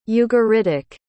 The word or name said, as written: ugaritic